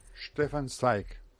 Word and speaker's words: Stefan Zweig: Stefan Zweig.